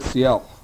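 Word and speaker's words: Si'ahl: Now, needless to say, most white people could not say Siel. That last pronunciation just didn't come across. And all sorts of various pronunciations of his name came out. But somehow Seattle was the one that stuck.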